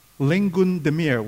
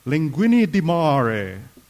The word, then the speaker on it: linguine di mare: It's not linguine di mare, if I read just English, right? It should be pronounced linguine di mare.